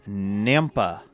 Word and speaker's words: Nampa: Ryan Driscoll of Nampa, Idaho, rhymes with Tampa.